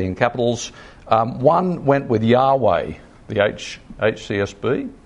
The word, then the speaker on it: Yahweh: And the Jews didn't like pronouncing it, so they put in the vowels of Adonai over what we now think is probably pronounced Yahweh.